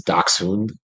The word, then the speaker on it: Dachshund: Well, since it's actually that's how it's pronounced in German where they came from, is Dachshund.